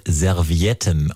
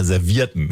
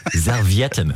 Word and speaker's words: Servietten: Oh, Rainer, da ist mir gerade positiv aufgefallen, dass Marc eben sehr, sehr präzise das Wort Servietten ausgesprochen hat. Ich sag immer Servierten. – Boah, das tut mir richtig weh. – Ich sag immer Servierten. Servietten.